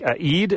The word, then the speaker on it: Eid: It sounds like Eid. Am I saying that right?